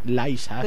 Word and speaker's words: Lisa: Que Laisa, por cierto, no es Lisa, como se escribe en inglés. Es Laisa.